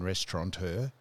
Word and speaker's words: restaurateur: There's a man, a Michelin hat man, who was a restaurateur, no N in restaurateur.